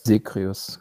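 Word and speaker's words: Zagreus: Wie spricht man es aus? Saegreus? – Segreus. Ja, Ja, genau, auf jeden Fall.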